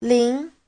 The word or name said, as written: lin2